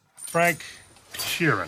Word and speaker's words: Frank Sheeran: Frank Sheeran.